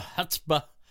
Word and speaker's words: chutzpah: Even if he lost in 20 seconds, I would think, man, he had the guts, the chutzpah to do that. That's pretty cool. – You pronounced that word correctly. And it doesn't pronounce like it's spelled. – No. – Chutzpah.